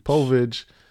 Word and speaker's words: Povich: Povich launches a DNA paternity test company offering at-home testing services for families in search of answers.